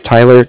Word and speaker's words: Tyler: If you could just put down that my name was Tyler, T-Y-L-E-R, and I'm calling with Cardiff. It's spelled C-A-R-D-I-F-F, as in Frank.